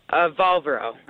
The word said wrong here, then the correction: Valero: I forgot how to say it. Like Valero, right? – Yeah, Valero.